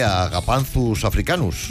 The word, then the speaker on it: Agapanthus africanus: Porque es mucho mejor llamar Josefina o Pepita a una planta que Agapanthus africanus. – Madre mía. – Muy bien, oye, muy bien pronuncia esa Agapanthus.